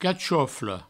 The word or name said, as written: cachofle